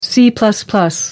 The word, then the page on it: c. northcote parkinson: American English